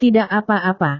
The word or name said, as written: tidak apa-apa